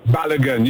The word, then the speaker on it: Balogun: There's a young man, I believe you pronounce his name, Falaron Balogun. Did I say that right, or am I messing up that name? – Balogun, you got it right, Myron.